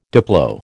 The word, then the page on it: diplo: American English